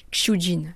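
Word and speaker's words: Qiu Jin: moi je dis Kyujin, mais en fait ça se dit Jin normalement. Voilà, prononciation chinoise.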